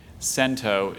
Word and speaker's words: cento: Cento is the only pronunciation.